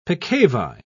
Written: peccavi

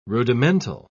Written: rudimental

